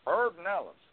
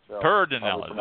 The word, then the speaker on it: Pedernales: Trying to pronounce that like LBJ. – Pedernales. – Pedernales. There you go. – There you go. – Emphasis. Pedernales.